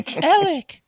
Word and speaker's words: Alec: Well, it's spelled like Alex, but it's pronounced Alec. – I don't think you understand. I have A-L-E-X, Alex, saved in my phone for you, Alec.